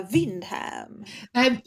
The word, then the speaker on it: Windhamn: Ska man säga Windhamn och liksom betona enet, eller ska man säga Windham? – Nej, Windhamn. Exakt Windham. Så som det stavas.